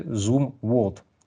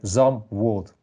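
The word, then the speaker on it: Zumwalt: На данном этапе речь пойдет о замечательным, сверхсногсшибательном, сверхъестественном во всех отношениях эсминце Zoom World или ZAM World. Ну, пишется Z-U-M-W-A-L-T. E Читать можно по-разному, а вопрос э, не в этом. Скорее всего, все-таки ZAM World. Давайте прочитаем так, как читают они. Французы говорят «Зюмвальд», отсюда у меня а, такое разночтение.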